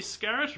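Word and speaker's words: Scarratt: Women's player of the year, Emily Scarrett, rhymes with Barrett.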